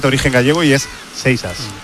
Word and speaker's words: Seixas: Exactamente os lo voy a decir. Lo que pasa es que luego cada uno lo dirá como quiera, pero es Seixas. Paul Seixas. Así se pronuncia, Seixas.